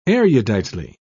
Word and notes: eruditely: British English

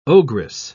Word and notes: ogress: British English

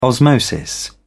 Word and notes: osmosis: British English

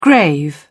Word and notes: grave: British English

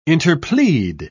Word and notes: interplead: British English

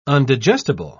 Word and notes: undigestible: British English

